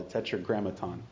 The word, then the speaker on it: tetragrammaton: It's called the tetragrammaton. And if you want me to spell that, TETRAGRAMMATON, tetragrammaton.